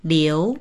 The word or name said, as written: liu2